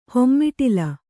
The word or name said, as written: ಹೆಮ್ಮಿಟಿಲಿ